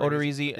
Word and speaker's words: Odorizzi: I do like the Odorizzi. Um, um, I never know how to pronounce his name. Odorizzi. Odorizzi yeah.